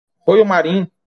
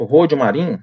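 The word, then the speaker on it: Royo Marín: É também o Royo Marim, o Rodio Marim, depende da, da, da, forma como quiser pronunciar.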